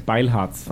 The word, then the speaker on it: Beilharz: Yeah, how do you pronounce it? – In German? – Yes. – Beilharz. – Oh, Beilharz. – Nobody gets it right, even in Germany, so that's okay.